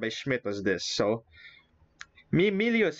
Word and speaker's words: Miglio: Oh, it's Miglio. The G is silent.